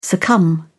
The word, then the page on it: succumb: British English